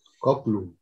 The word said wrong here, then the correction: Goldblum: Goldblum, ajá, nunca sé pronunciar su apellido, pero vamos a ese sector.